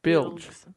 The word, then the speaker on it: bilge: bliges. – Bliges. – Bligs. – How do you spell that? – B-L-I-G-E-S. No, B-I-L-G-E-S. Bilge.